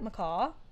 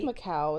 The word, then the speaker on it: Macau: Is that how you say that? Macaw? I think it's Macaw. No, that's how you say it when it's a bird. – Yeah, Macaw is like M-A-C-A-W. This is a U. I feel like Macau is probably... – Macau.